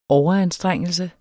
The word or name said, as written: overanstrengelse